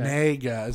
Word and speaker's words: Negga: N-E-G-G-A. Ruth? – She's Nega? Ethiopian. That's not a... Nega. – Nega? Okay. – Nega is how you say it. Phonetically, right here on Wikipedia, Nega.